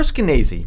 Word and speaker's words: Skenazy: I got to say, Lenore Skenazy, rhymes with crazy, has come up with a niche.